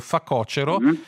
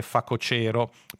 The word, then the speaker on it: facocero: propongono come corretta la pronuncia con l'accento sulla penultima, accettando facocero, ma dicendo che quella più giusta dovrebbe essere facocero.